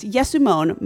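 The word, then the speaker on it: Yesumon (יישומון): You are correct. Yesumon, my word, is the right way to say application in Hebrew.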